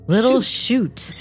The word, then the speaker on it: Little Chute: It's spelled S-H-U-T. – C-H-U-T-E. – Oh, E. Oh, okay. – Little shoot. Like it's a shoot. not S-H-O-O-T.